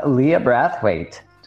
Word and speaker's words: Leah Brathwaite: Welcome, Leah Brathwaite.